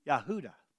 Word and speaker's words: Yehuda: If you're going to pronounce Judah in Hebrew, it'd be Yehuda.